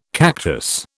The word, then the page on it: cactus: British English